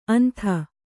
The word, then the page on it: ಅಂಥ: antha